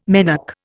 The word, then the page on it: menarche: Indian